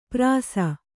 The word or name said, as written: ಪ್ರಾಸ